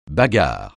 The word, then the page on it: bagarre: francais